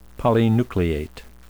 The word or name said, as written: polynucleate